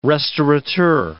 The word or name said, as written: restaurateur